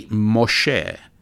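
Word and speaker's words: Moshe (משה): And so uh, Moses is really Moshe, and Jonah is really Yonah and Noah is really Noach.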